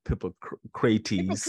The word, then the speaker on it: Hippocrates: Hippo, Hippocrates. – Hippocrates. Hippocrates. – Like Socrates. Yes, and these people that you mentioned um, were some of the, the greats.